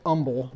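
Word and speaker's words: humble: Some people make more of them silent than others, like herbs. Most of us don't say herbs, but some people say umble, which is really strange, Judy.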